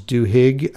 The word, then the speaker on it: Duhigg: In this episode, the host interviews a Pulitzer Prize-winning journalist, Charles Duhigg, I think is how you say his name. It's D as in Delta, U-H-I-G-G.